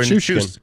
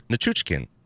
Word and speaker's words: Nichushkin: Did I say that right? Nichushkin. Is that Chooch or Nichushkin? – Nichushkin. – Nichushkin. Nichushkin. – Yeah. – Not skin, like Teddy Ruxkin.